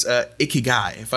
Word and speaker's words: Ikigai: But listen, I'm curious, what on this last little bit here, what exactly is Ikigai? Am I pronouncing that correctly? – Pronounced it beautifully.